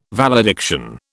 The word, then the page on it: valediction: British English